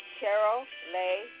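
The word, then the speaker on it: Cheryl Leigh: The address is 1698 Carolay Drive. The street is spelled C-H-E-R-Y-L. Second word is L-E-I-G-H Drive.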